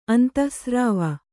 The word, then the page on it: ಅಂತಃಸ್ರಾವ: kannada